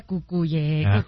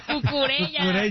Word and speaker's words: Cucurella: No, pero se pronuncia cucuyella. Ya no puedo. Cucurella.